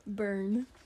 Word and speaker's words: Bern: Bern. – Spell it. – B-E-R-N. – Okay. – It's like barn with an E.